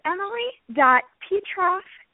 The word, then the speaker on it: Petroff: It is emily.petroff.